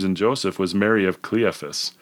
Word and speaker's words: Cleophas: How do you say that? – I think Cleophas is how I right. heard it, yeah.